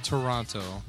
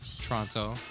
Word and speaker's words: Toronto: Not Toronto. Toronto. – Toronto. – I like That's how they say it over there.